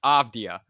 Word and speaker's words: Avdija: Without a doubt, and I'll first say your pronunciation was only a little bit off. It's actually just Avdija. – Avdija.